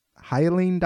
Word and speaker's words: Hyaline: One of these documents you titled the Hyaline document. Am I pronouncing that right? – Yeah, that's great.